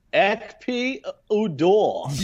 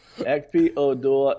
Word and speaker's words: Ekpe Udoh: Ekpe Udoh. – Yeah. – What name I'm going with. Ekpe Udoh, and he's a guy that's going to get an opportunity to start.